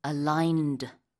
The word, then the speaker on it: aligned: So how do you spell aligned? – A-L-I-G-N-E-D. Aligned. – Exactly. There's a G in there. Yeah. G-N이 들어가는데요. Ligand가 아니고 aligned 이렇게 발음을 합니다.